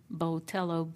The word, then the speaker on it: Botello: My Twitter, if you want to follow me, is S-U-S-Y Susie Botello, B-O-T-E-L-L-O.